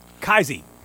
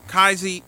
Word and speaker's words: Kyze: Now, I don't know how to pronounce that. It's spelled K-Y-Z-E. – Kaize. – Kaize.